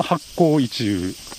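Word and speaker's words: 八紘一宇: あ、これは今そのまま読むと八紅一羽という読んでおります。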